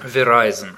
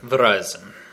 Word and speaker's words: Verizon: Или как он там правильно говорит? По-моему, Verizon так и говорится. – Verizon. Verizon.